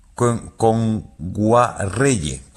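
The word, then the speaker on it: Kngwarreye: Nwaegere, lo he dicho mal. Emily Kame. – A ver, Emily Kame. – Con Guarreye. – Emily Kame con Guarreye, sí, sí.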